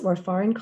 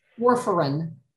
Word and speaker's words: warfarin: Somebody just asked, can taking warfarin, is that how you pronounce it? Warfarin cause weight loss? – Warfarin.